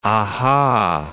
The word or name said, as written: aha